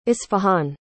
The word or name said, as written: isfahan